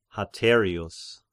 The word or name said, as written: Haterius